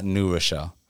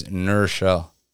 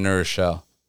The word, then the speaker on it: New Rochelle: It's not New Rochelle. – Yep. – It's Nurchelle. That's how New Yorkers say New Rochelle. – I can't say it properly, but it's one word. It's Nur. You don't say the W, it's Nurchelle.